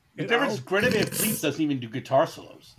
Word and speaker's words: Van Fleet: Am I saying that right? – I actually think they might be. – Is that the name of the band, Greta Van Fleet? – Van Fleet, yes.